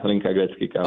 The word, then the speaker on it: Hlinka-Gretzky Cup: You had the chance to play, is it the Hlinka-Gretzky Cup? Is that how you say it? – Oh, yeah, Hlinka-Gretzky Cup.